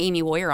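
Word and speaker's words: Amie Wohrer: I'm the only Amy Warrior on earth, by the way.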